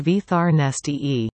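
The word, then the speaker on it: Viðarneisti: Concluding the performances at SAUC in February of 2023 as Viernisti, clarinet and marimba duo Catherine Wood and Victoria Sparks, come together as Viernisti, pronounced Vithar Nesti.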